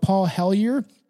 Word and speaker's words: Hellyer: And there is a guy that we'll eventually talk about that I do have an episode written about Paul Hellyer.